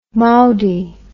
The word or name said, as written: mowdie